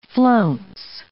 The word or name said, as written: flounce